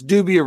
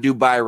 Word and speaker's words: Dubia: It's Dubia roaches, not Dubai roaches. They're not from Dubai, India. They're Dubia roaches. They're goddamn cockroaches. It's five letters. There's literally a syllable and a half in there, and you're fucking that up.